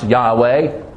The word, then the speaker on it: Yahweh: And we know that it is pronounced Yahweh. We're pretty certain of that because Yah is found in the word Hallelujah.